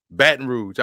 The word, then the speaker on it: Baton Rouge: Baton Rouge.